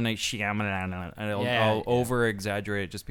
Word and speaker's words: Shyamalan: So for the listeners at home, that is how you properly pronounce his last name. There isn't a Shyamalan, it's just Shyamalan. M.